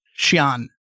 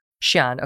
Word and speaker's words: Xi'an: Is it Xi'an? I'm not sure how you pronounce it. Xi'an. Xi'an. Okay, thank you.